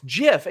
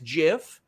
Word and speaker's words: GIF: Listen, somebody's saying it's a hard G, Sean, for GIF. No, it's GIF. And you want to know why it's GIF? It's because the guy who literally invented it says it's GIF.